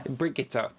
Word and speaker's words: Brigitte: But also, I think Brigitte Helm, I hope I'm pronouncing that right, as somebody with German relatives. – Brigitte, thank you. – Brigitte. – I knew you'd be able to correct me on that. I think she's a pioneer.